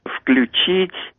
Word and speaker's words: включит: Как правильно ставить ударение в формах настоящего времени? В их словаре было неправильно «включит» и не рекомендуется «звонит». Это запретительные пометы были. Мы «звонит» тоже не допускаем. Наш словарь тоже написали «не рекомендуется». А «включит» мы написали «допустимо включит». Это процесс идет все время в разных словах. «Включит». Молодежь только так и говорит. Очень многие люди говорят «включит».